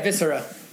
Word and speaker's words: Viscera: You just mentioned how you did the one with the Psalms, but also now with your most recent release, Viscera, is that how you pronounce it? – Yeah, Viscera.